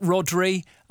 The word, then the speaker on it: Rodri: Big shoes to fill without Rodri, arguably the best player in the world.